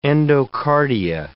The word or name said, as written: endocardia